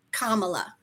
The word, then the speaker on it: Kamala: So even though inflation is up, we've heard Kamala talk a lot, and I do not mean to mispronounce her name a lot. – Kamala. It's just Kamala.